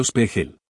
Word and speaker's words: Spiegel: El teléfono fue contestado no por Eric sino por un tipo que dijo que su nombre era Henry Spiegel, que pronunció Spiegel.